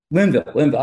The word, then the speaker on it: Linville: It's Levine, right? The last name is Levine. Did I pronounce that right? Dr. Linville. – Linville. I'm sorry. Linville.